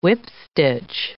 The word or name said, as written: whipstitch